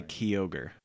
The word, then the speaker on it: Kyogre: Let's skip down to that K-Y-O-G-R-E. How do you say that? – I would say Kyogre. – Kyogre. I think that's how it's officially pronounced.